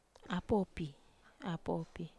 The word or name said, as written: apopi